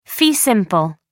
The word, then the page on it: fee simple: American English